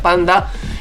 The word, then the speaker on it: PXNDX: o PXNDX, pero se pronuncia Panda con su tema Cita en el quirófano.